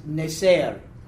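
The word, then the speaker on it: Netzer (נצר): C-H-O-T-E-R. C-H-O-T-E-R. The pronunciation is Hoter. Okay? And the branch is Netzer. Netzer. N-E-T-Z-E-R, N-E-T-Z-E-R, Neser.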